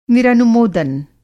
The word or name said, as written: निरनुमोदन